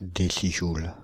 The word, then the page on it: décijoule: [de.si.ʒul]; Paris